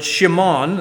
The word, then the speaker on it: Shimon: And Simeon actually comes from the Hebrew word Shimon. That would be the pronunciation of it.